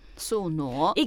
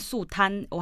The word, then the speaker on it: 宿儺: 对，好，那就是两面宿儺。 – 宿儺，一个人在一个男哦，不念贪哦，嗯、念儺，呢乌哦儺二声儺。